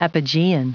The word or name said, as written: apogean